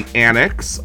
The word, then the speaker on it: Anix: You showed us the song Don't Let the Outside Win by The Annix, which it's spelled A-N-I-X for people that are not familiar with The Annix.